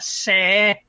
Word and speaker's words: Saer: No, that's S-I-A. – S, what do you say? S-A-E-R. – S-A-E-R. Sair. – Sayer? – S-E-R?